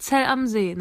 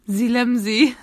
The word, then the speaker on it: Zell am See: Und die Araber sagen Silemsee. Ja, wir waren in Selemsee. – Hat das übersetzt irgendeine Bedeutung, oder? – Nein, das ist einfach falsch ausgesprochen. Selemsee, nicht Zellamsee.